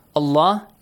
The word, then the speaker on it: Allah: يَلْعَنُهُمُ يَلْعَنَهُمُ نُو يَلْعَنُهُمُ اللَّهُ Allah is pronounced heavily. Why? Because Dhamma sound is before. If there is a kasra, you pronounce it lightly, yes?